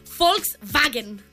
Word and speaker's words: Volkswagen: Doncs mira, no sé si donar-te el punt sencer o mig, perquè efectivament la paraula que tots coneixeu com Volkswagen és Volkswagen.